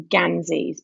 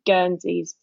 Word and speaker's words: Guernsey: And I know that people sometimes pronounce them ganzies, but that's a later development. They were always originally Guernseys from Guernsey, and we still call them Guernseys on the island. No one would call them ganzies here.